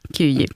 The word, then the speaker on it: Kiev: gick över till Kiev, om det nu är ungefär sådär som det uttalas. – Ungefär så, Kiev.